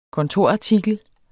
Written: kontorartikel

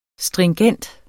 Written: stringent

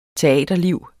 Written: teaterliv

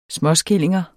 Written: småskillinger

